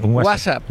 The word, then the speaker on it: WhatsApp: No, peor aún, con acento en la primera, WhatsApp, ¿no? – Un WhatsApp.